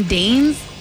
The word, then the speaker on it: deigns: A woman deigns. Deigns. Deigns. – Spell it. – D-E-I-G-N-S. – Oh, I don't know what that is. – That killed us all. – Yeah. – Silent G words.